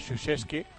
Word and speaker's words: Krzyzewski: Empieza con K, R, Z, Y. En esta típica situación de muchos apellidos polacos, a él se le conoce, por lo menos la pronunciación en inglés de su apellido es Krzyzewski,